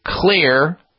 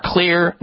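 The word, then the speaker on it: Xlear: And you also have big box stores, drug stores, CVS, Rite Aid, and other retailers that carry Clear, spelled with an X, X-L-E-A-R, Clear Nasal Spray products.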